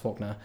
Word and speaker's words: Falconer: But now we're going to move to a guy called Richard Falconer, I think it's pronounced Faulkner, of St. Andrew's Ghost Tours.